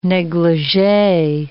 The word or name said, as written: neglige